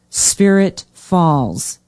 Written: spirit falls

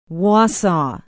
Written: Wausau

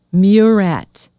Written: Murat

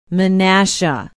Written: Menasha

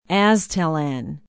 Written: aztalan

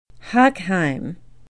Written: hochheim

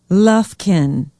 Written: lufkin

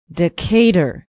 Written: Decatur

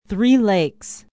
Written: three lakes

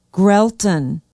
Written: grellton

